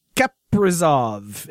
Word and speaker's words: Kaprizov: It's Kaprizov is how you do it, and that's how you Kaprizov.